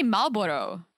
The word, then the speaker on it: Marlboro: We say Marlboro in Czech. – Marlboro. – Is it Marlboro? – Marlboro. I think it's Marlboro. – We don't pronounce the R.